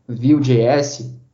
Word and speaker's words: Vue.js: Com Angular, com Node.js, com React, React Native, com Vue.js? Eu acho que Vue, que é, esse framework se pronuncia desse jeito, Vue.js, que é coisa vocês podem até me corrigir.